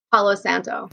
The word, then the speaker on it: Palo Santo: Palo Santo? What is that called again? – Palo Santo.